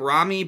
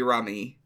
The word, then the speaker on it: Rummy: They say it, in the dub they pronounce it like Rami, but I think officially it's supposed to be Rummy.